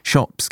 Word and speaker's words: Swapan: Shop's given name is actually spelt S W A P A N, and for years he let people mispronounce it, Swapan.